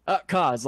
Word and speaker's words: Kaz: Kaz, like Kaz in effect.